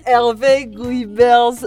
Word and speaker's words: Hervé Guibert: The journal Ash reads from is Hervé Gouvet. The journal Ash reads from is Hervé Guibert's The Mausoleum of Lovers.